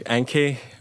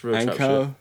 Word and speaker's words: Enk3i: Yeah, it's like Enki, but like with a three, so it's like kind of edgy.